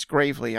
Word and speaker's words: Gravely: Besides Gerald Meerschaert and Tony Gravely, that's how you say his name. It's Gravely.